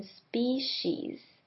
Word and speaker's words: species: 那这个字的发音也比较特别， species 第一个 e 发成长母音 e 的声音 species。那这边的 c 发成像 sh 一样 sh 的声音 species Evolved in species that have multiple young.